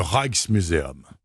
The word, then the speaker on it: Rijksmuseum: Alors il y a le Rijksmuseum.